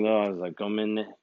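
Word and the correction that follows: gomenasai: I'm supposed to say gominasai. And like Tade said, gominasai.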